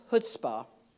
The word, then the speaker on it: chutzpah: Okay, chutzpah, pronounced chutzpah.